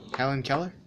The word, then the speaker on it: Helen Keller: You don't know what's Helen Keller? – Oh, Helen Keller. – Oh, yeah. That's how you say it? – Helen Keller.